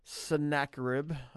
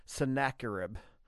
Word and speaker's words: Sennacherib: I figured out how to say the name Sennacherib. Sennacherib. I looked it up, make sure I wasn't sounding too crazy. Sennacherib. So, snack crap.